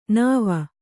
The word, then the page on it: ನೆವ: kannada